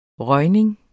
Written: røgning

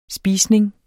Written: spisning

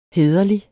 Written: hæderlig

